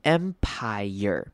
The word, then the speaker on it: empire: 好，empire 这个字比较特别，它虽然结尾是 P-I-R-E，很像是念短母音长母音 R 就好，但是其实习惯上会把这个字念成三个音节，会念成 empire。 Empire，所以不会念 empire 就结束，会念成三个音节 empire，后面会多一个 year 的声音，所以这个我们这个字我念快一点，会念成 empire，or like empire。